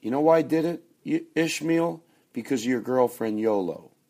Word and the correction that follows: Yola: I hope I say her name right. She gave me Yola. Yola. There you go.